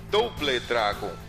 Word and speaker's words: Double Dragon: Nada de Double, é Double Dragon.